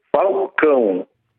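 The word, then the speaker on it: Falcão: Allora, non si è mai capito se la pronuncia giusta è Falcao o Faucon, come scuola è la pronuncia giusta? – Questo mai riesce a parlare Faucon. – Vabbè, più o meno l'abbiamo capita, è la seconda.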